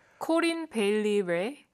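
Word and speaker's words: Corinne Bailey Rae: 어떻게 읽어요? 코린 베일리 레. – 코린 네. 베일리 레라고 읽어요? – 네네.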